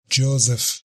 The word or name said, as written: Joseph